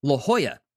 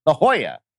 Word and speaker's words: La Jolla: La Jolla. – La Jolla, this fall, out in California.